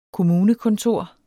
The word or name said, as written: KOMMUNEKONTOR